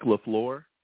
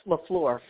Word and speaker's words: LaFleur: Mike LaFleur. – Yeah, LaFleur.